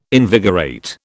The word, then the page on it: invigorate: British English